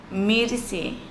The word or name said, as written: miriSi